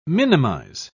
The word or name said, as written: minimize